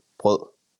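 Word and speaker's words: Brød: Brod, is that how you say it? – Brod. – With B-R-O with the line through it? – Yes, D? – You say Brod.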